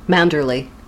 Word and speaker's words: Manderley: How do you pronounce it? Mandalay or Mandalay? – Manderley. – Really? – Yeah, that's how I would pronounce it.